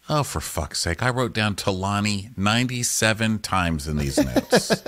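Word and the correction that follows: Taleen: I thought it was Talene. Yeah, Talene. It's T-A-L-E-E-N.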